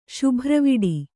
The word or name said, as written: ಶುಭ್ರವಿಡಿ